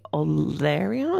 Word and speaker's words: Olearia: I have some stunning little smoky olearia.